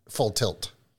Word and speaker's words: full tilt: Full till. Yeah. – Did I say that right? – Full tilt.